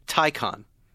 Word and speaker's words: Taycan: Taycan. Tie like a tie like you're wearing and a con like you are as well. Taycan. – I thought it was a Taycan. – Pronounced confusingly Taycan.